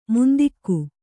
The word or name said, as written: ಮಂಥಿಕ